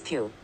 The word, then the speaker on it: Pugh: Florence Pugh.